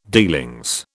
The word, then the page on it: dealings: British English